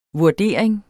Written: vurdering

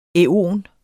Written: æon

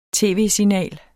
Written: tv-signal

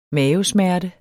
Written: mavesmerte

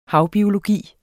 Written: havbiologi

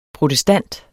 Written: protestant

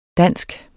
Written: dansk

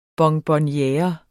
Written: bonbonniere

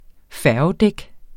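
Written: FÆRGEDÆK

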